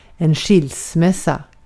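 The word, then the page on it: skilsmässa: Swedish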